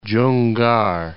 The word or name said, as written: Junggar